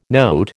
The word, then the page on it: note: American English